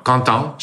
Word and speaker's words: Quentin: Quentin.